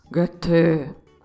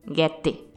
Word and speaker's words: Goethe: Como já deve ter sido possível deduzir pelo título do programa, eu hoje vou falar dos vales da uva Goethe. Parece que é meio assim que fala. Eu consegui um alemão para me ensinar a pronunciar. Olha só. Goethe. Bom, o pessoal lá de Santa Catarina com quem eu conversei diz guete, com aquele sotaque deles.